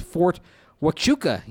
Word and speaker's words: Fort Huachuca: It's pronounced Fort Huachuca, gents.